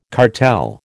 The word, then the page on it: cartel: American English